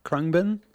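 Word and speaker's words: Khruangbin: Krungbin? Krungbin? – Beautiful.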